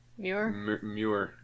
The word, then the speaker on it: Muir: There was a place that we went to in Atlanta, the General Muir. General Muir. – Muir? – Muir. General Muir. – Spell that? – M-U-I-R.